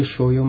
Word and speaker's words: Shoujo: Shoujo is dus de manga en de anime voor meisjes of voor vrouwen.